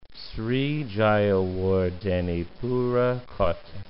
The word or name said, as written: Sri Jayawardenepura Kotte